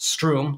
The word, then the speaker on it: Stroom: Now, I might not use any of these terms, but my friend Thomas Wadsworth, the chief product officer at Stroom, which is again pronounced Stroom like broom, um, may use them.